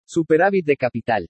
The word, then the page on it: SUPERÁVIT DE CAPITAL: spanish